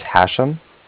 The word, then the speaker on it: Hashem: My name is Hashem. That's H-A-S-H-E-M, as in mother at the end. – Okay. – And I'm calling from Bank of Cardiff. That's spelled C-A-R-D-I-F-F, like Frank at the end.